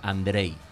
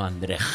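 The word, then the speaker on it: Andrzej: Me comentan por el pinganillo de Facebook que se pronuncia Andrei. – Andrei. – Andrei, no Andrej. – Andrej, bueno, no, Andrei.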